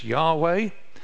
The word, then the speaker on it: Yahweh: In, if you were to pronounce it in Hebrew, the particular name that the God of the Bible has is Yahweh.